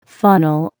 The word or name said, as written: funnel